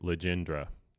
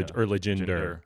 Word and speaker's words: Legendre: And apparently, phonetically, it's spelled Le Gin, like the drink, Legendre. So, okay. – Legendre.